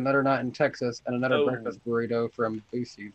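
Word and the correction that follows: Buc-ee's: he says. – Bucky's. – Or Bucky's, that's how you say it.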